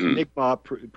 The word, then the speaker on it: Mi'kmaq: See, and the Mi'kmaq, M-I-C-M-A-C written like that, and the Mi'kmaq of the East Coast, pronounced Mi'kmaq properly, they're the ones that, you know, that brought me into their, I was adopted into the tribe by ceremony.